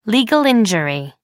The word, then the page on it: legal injury: American English